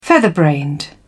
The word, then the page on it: featherbrained: British English